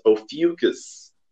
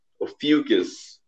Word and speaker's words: Ophiuchus: The uh, zodiac sign is I thought it, I thought it was pronounced Ophiuchus, but uh, I, I, had to dig. It's pronounced Ophiuchus. Ophiuchus.